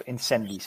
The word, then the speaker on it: Incendies: Mijn nummer drie is uh, eh, Incendies of Incendies. Dus moet ik op zijn Frans uitspreken.